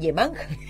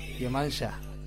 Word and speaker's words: Yemanjá: Ah, no, porque dije Yemanja. Yemanjá.